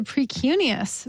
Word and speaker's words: precuneus: Tell me if I'm pronouncing this correctly. The Precuneus? – Yes, the Precuneus. Very nice.